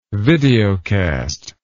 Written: videocast

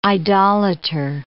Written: idolater

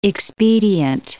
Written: expedient

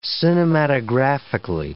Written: cinematographically

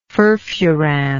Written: furfuran

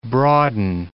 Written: broaden

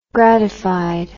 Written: gratified

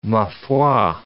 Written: ma foi

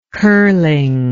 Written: curling

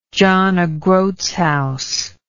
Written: john o groats house

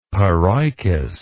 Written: paroicous

